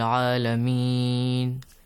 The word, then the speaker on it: Alameen: Alhamdulillah Rabbil Alameen. – This sound at the end. Did you hear it? – Yes. – Alhamdulillah Rabbil Alameen. This long sound.